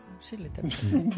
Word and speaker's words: Shirley Temple: Muy bien, pues en el siguiente escalón, el número 18, tenemos a Shirley Temple. Supongo que se pronunciará así. – Sí, Shirley Temple.